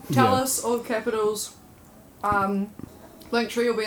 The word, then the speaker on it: Talliss: Talliss. It's like Tegan, like the A is pronounced the same.